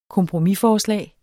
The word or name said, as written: kompromisforslag